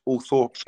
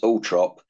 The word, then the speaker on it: Althorp: Althorp Estate. – Oh, right. I think you'll find it's pronounced Althorp, but, you know, that's just local knowledge.